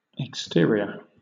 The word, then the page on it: exterior: British English